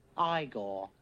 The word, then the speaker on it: Igor: No, it's pronounced Igor.